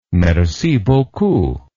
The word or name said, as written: merci beaucoup